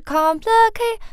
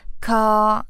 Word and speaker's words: complicate: 就很正常的三个音 complicate，它不是 complicate，它是平的 complicate。